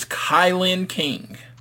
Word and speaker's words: Kilynn King: We then had Mel getting the victory over Killian King. I believe I pronounced, or was it Killian King? Did I pronounce that right? It is Kilynn King.